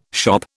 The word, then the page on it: shop: British English